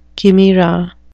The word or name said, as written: chimera